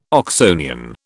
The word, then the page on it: Oxonian: British English